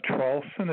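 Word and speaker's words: Traulsen: Charlson. It's spelled T like Tom, R-A-U-L-S like Sam, E like Edward, N like Nancy.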